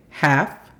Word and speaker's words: half: La pronunciación fonética de esta es J-A-F, half. Y past otra vez, P-A-S.